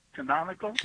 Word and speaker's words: canonical: And my question is when, because I know they, the canonical books. – I said that right? – Yeah.